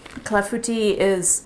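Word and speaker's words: clafoutis: call it clafouties, which is much more fun to say than clafu tea. It's C-L-A-F-O-U-T-I-S, hence the French pronunciation, and hence the childish, sounds like a disease, American pronunciation.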